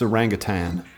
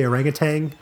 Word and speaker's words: orangutan: It's orangutan. – Yeah, I know, but don't people say orangutan? – Incorrectly, yes.